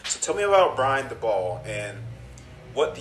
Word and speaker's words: Daboll: Yeah, so Brian Daboll, I think Daboll is the way that he likes it pronounced, but I know some beat reporters that kind of go either way.